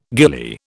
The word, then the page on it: gillie: British English